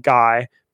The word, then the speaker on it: Guy: I think everyone should read Guy Debord's, it's spelled Guy, but it's, I think it's Guy Debord's Society of the Spectacle.